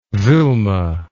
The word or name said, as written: vilma